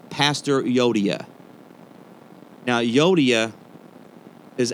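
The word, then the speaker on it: Euodia: It was an actual person in the Bible that Paul was referring to, and that's how you pronounce this person's name was Yodia. It's spelled E-U.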